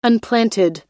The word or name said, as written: unplanted